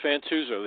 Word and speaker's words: Fantuzzo: Is it A little bit. It's Fantuzzo. The A is silent. But, yeah, you're very close.